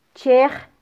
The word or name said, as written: chej